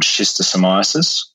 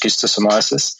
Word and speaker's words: schistosomiasis: There's also another specific disease that is present in Mozambique and affects a large proportion of the population called schistosomiasis, or sometimes pronounced schistosomiasis.